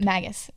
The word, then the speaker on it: Magus: Magus. It's like mage.